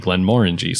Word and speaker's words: Glenmorangie: I used to say Glenmorangie, and then I watched a couple things on distilleries, and like the distillers there were saying Glenmorangie, say it like it's an orange.